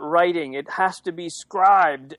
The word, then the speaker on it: Scribd: And I'm like, it's spelled like scribed, where they just took away the E, like in the funky cool internet thing to do, right?